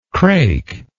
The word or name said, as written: crake